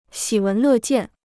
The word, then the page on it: 喜闻乐见: xǐ wén lè jiàn